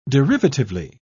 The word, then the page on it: derivatively: British English